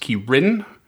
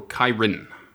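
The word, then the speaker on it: Ki-rin: According to the Forgotten Realms wiki, it's pronounced Kirin or Kyrin, with the emphasis on the second syllable.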